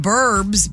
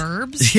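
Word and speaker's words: birbs: They're talking about birds and they say burbs? – Yes. – What in the world? – It's not slang. That's just dumb. – That's just not pronouncing the word correctly.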